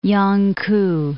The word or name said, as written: Yangku